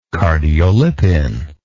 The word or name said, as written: cardiolipin